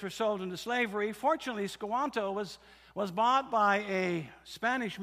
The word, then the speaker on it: Squanto: That's spelled S-Q-U-A-N-T-O, Sequanto.